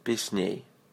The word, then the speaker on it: Песней: Second goes as this. Песней. Say ней. Песней. Accent on the second syllable.